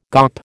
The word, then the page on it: gawp: American English